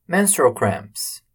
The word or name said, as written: menstrual cramps